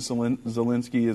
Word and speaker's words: Zelensky: I think even President Zelensky is, that's not how you say his name, is it? – Yeah. It is. – It is. Okay.